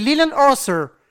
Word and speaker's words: Leland Orser: Mais je le prononce mal, c'est Leland Orser, Voilà.